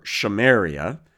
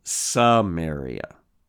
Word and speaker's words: Samaria: Now, because of changes in pronunciation over time, Shimron, or Shamaria, becomes the softer sounding Samaria. And from here on out, I will use that softer later pronunciation for the name, okay?